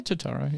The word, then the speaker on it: Turturro: So the person who's actually playing pool, though, is Julian, played by John Turturro. Is that how you pronounce his name? – Yeah, Turturro, yeah.